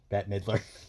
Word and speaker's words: Bette Midler: Bette Midler.